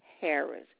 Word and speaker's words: Harris: Deborah, D-E-B-O-R-A-H. L as in Larry, Harris, H-A-R-R-I-S. – I have D-E-B-O-R-A-H.